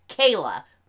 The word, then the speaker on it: Kayla: But I am detracting 1 full fleece blanket for having a character whose name was spelled K-A-Y-L-A, which is Kayla. My name, Kayla, and pronouncing it Kyla.